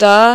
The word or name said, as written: ट